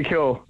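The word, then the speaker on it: Keogh: It's Evan Murphy Kyo.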